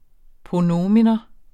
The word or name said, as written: pronomen